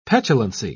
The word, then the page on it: petulancy: British English